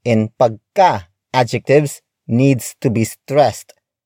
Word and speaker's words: pagka: Another way of creating superlative adjectives is by adding the prefix pagka. Pagka. Pagka. Pagka is spelled as P-A-G-K-A.